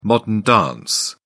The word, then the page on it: modern dance: British English